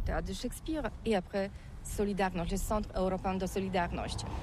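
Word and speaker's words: Solidarność: Alors solidarité, en français on dit Solidarnosc, mais ici à Gdansk on ne prononce pas Solidarnosc. – Solidarnosc.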